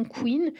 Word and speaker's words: Quinn: Son nom, hors le jeu de mots avec Harlequin, vient aussi de la prononciation de son nom Queen, qui se prononce comme Queen,